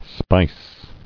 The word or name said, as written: Speiss